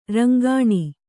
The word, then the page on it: ರಿಂಗಣ: ringaṇa